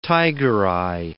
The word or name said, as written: tigereye